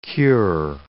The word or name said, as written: cure